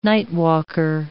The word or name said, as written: nightwalker